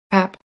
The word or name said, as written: Pap